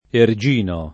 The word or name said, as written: Ergino